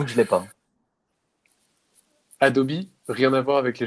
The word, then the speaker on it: Adobe: Adobe, mm-hmm oui, on prononce Adobe en anglais.